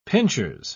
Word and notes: pinchers: British English

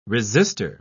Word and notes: resister: British English

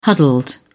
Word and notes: huddled: British English

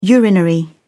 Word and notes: urinary: British English

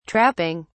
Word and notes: trapping: British English

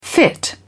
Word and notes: fit in: British English

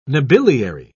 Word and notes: nobiliary: British English

